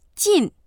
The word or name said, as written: jin4